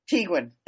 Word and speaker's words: Tiguan: Tiguan.